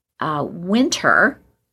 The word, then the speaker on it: Whynter: Good Housekeeping lab pick is Winter, and that's spelled W-H-Y-N-T-E-R.